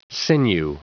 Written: sinew

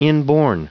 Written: inborn

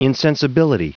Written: insensibility

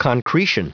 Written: concretion

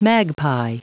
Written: magpie

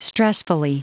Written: stressfully

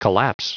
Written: collapse

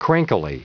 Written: crankily

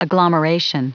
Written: agglomeration

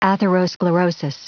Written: atherosclerosis

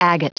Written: agate

